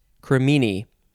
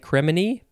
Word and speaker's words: cremini: Cremini. I would probably want to say cremini, but no, it's cremini. C-R-E-M-I-N-I, or you can spell it with all I's.